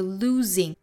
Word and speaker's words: losing: 那我们来看第二个词，这个 losing 中间这个字母 s 应该对应的是浊音的 z 的发音，所以 lose lose 再加后面的 ing 就把这个 z 的音发得更加清楚了。